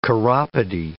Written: chiropody

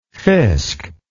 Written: fisk

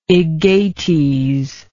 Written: aegates

